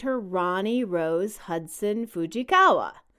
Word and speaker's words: Rani: Ronnie was named after Danny's father, Ron, but it's Rani. It's a Hebrew name meaning joyous song. And I don't know why they just didn't spell it with an O like your daughter. People are going to pronounce it Ranny.